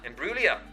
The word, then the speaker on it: Imbruglia: And she'll be joining the list of performers, including Jack Savaretti, Natalie Imbruglia, I think that's how you pronounce her name. – Imbruglia.